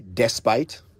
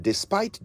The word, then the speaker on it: despite: D-E-S-P-I-T-E. Now, how would you pronounce that? Did you say despite? Yes, that's how so many people say it. I'm sure this is at least in part due to the presence of a popular Ghanaian media mogul whose nickname is pronounced that way. But despite, the correct pronunciation is despite.